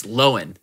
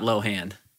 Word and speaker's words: Lohan: It's Lowen, not Lohan.